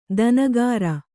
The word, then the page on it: ದಿನಕರ: dinakara